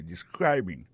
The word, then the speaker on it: describing: Sa nou ka wè la, sa nou constate la, nou ka constate que li a prononcé mot de describing. Accent tombé sous deuxième syllabe la.